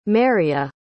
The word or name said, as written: meria